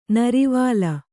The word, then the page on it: ನೀರುವಲೆ: Kannada